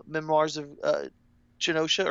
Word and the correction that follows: Geisha: or no, how do you pronounce that? – Geisha. Memoirs of Geisha. Geisha. Yeah.